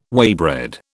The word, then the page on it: waybread: British English